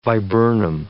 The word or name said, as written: viburnum